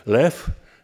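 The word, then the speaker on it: lew (לב): Mamy tutaj lew i, czasem się wymawia lep, ale zwykle lew, i lewaw.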